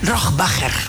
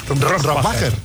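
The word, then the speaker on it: Rohrwacher: Bueno, ¿cómo se pronuncia Alba Rohrbacher Coixet? – Rohrbacher. – Rohrbacher. – Sí.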